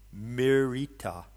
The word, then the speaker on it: Mirita: You pronounce it like the word miracle, Mirita.